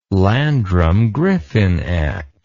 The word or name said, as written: Landrum-Griffin Act